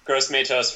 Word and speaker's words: Gross-Matos: And I want to apologize to Gross-Matos for pronouncing his last name as Moss. That was just a brain fart.